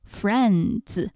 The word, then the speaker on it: friends: 那它的复数就是直接在结尾加上 s，变成 friends。要注意这个最后的读音变成了 z，因为这个的和 s 这两个音放在一起的时候会变成 z。